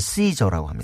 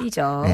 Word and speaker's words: SZA: 영어 스페링은 SZA, SGA인데요. – SZA인데 발음은 시저라고 합니다. – 시저.